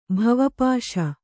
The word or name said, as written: ಭವಪಾಶ